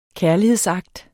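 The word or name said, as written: KÆRLIGHEDSAKTERNE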